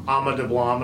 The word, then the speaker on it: Ama Dablam: but then you went and scaled Ama Dablam, and I hope I'm pronouncing that correctly.